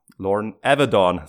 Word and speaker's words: Lauren Avedon: White hat er eben auch äh, den Lauren Avedon, so hat er ihn ausgesprochen und so ist es richtig.